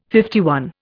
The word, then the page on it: LI: Indian